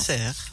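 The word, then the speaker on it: Sers: Serre, je ne sais pas comment on prononce. – Serre. – Serre, voilà.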